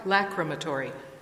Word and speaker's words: lachrymatory: L-A-C-H-R-Y-M-A-T-O-R-Y, lacrimatory.